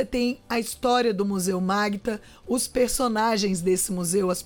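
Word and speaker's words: Magüta: É um vídeo de 9 minutos que foi produzido pelo pessoal da Secretaria de Cultura do Amazonas contando a história do Museu Magta. Museu se escreve Museu Maguta, mas se escreve Museu Magta.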